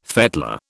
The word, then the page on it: fettler: British English